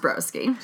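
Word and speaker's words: Broschi: Spelled the Italian way, but it's pronounced Broski.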